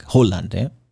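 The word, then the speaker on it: Højlund: It's pronounced something like Holland.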